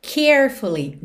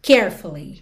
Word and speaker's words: carefully: Aqui, atenção a essa palavra listen, que embora tenha um T na escrita, para quem está ouvindo no podcast, L-I-S-T-E-N, mas o som aqui é de dois S, listen, ok? E a palavra carefully, normalmente, quando você fala essa palavra, a sílaba tônica é a primeira, carefully, carefully.